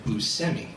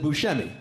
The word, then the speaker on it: Buscemi: I introduced you as Buscemi. Because of my extensive research, I learned that that's how you pronounce your last name. – That's true. – Even though everyone else in the entire world pronounces it Buscemi.